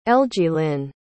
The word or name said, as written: elgielyn